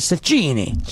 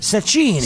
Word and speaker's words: Cecchini: How do you pronounce the Italian shortstop's last name? – Let me look at it. The Italian shortstop's last name is Cecchini. – Just say it again. – Cecchini. – Cecchini, okay.